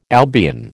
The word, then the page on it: Albion: American English